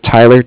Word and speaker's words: Tyler: My name is Tyler, T-Y-L-E-R. And I'm calling from Bank of Cardiff. It's spelled C-A-R-D-I-F-F, as in Frank Frank.